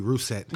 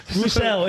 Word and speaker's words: Russell: Or Carly Russet. – Russet. Russell. It's Russell.